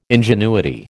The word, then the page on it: ingenuity: American English